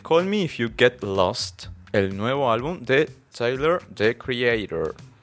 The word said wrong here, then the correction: Tyler: ¿Es Taylor? ¿Tyler? – ¿Cómo se pronunciará? Creo. Tyler. Tyler. – Porque, yo dije Taylor porque como no es Swift y se escribe sin la A, dije no sé.